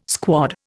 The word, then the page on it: squad: Indian